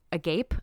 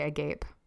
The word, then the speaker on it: agape: And everybody else is watching this, just like trying not to be mouth agape, a gap. How do you say that? – Yeah, I'd say agape.